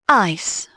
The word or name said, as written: ice